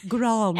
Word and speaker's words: Grande: Pronounce the E. – Grande. Any other lessons, guys?